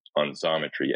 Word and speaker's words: Xometry: But getting your take on Xometry X, pronounced like a Z,